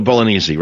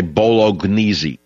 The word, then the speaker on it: bolognese: So she got the bolognese, right? The silent G. People say bolognese.